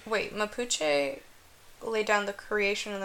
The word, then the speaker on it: Mapuche: Another type of mythology that comes from the same area is called Mapuche. Did I say that right? – Yeah. – Okay. Mapuche